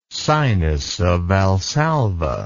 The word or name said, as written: sinus of Valsalva